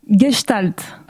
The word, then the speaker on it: Gestalt: Alors déjà, vous allez pouvoir me corriger, est-ce que je le prononce bien ? – Gestalt. – Ah. – C'est pas mal.